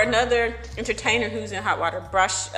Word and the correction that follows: Bryshere: Bryshere Gray.